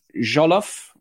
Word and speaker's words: jollof: I don't know if you're in there as well, for who in Africa has the best jollof rice.